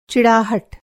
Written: चिड़ाहट

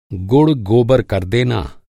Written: गुड़

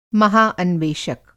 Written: महा अन्वेषक

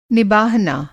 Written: निबाहना

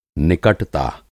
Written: निकटता

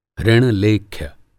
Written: ऋण-लेख्य

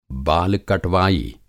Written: बाल कटवाई

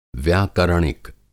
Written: व्याकरणिक

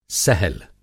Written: सहल

